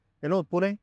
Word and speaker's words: Pune: In fact, you said it right. It is Pune.